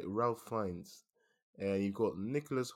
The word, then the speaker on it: Ralph: So, I discovered his name's actually pronounced Ralph Fiennes, even though it's spelled as if it's Ralph.